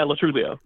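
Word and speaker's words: Lo Truglio: and Joe Latrulio. – Right, yeah. Is that how I say his name? – Yeah, Latrulio. – Latrulio. Okay. Yeah.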